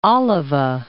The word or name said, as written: Alava